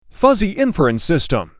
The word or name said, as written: fuzzy inference system